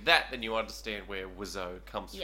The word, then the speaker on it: Wiseau: Tommy Wiseau. And that's how it's pronounced. Wiseau, according to the book.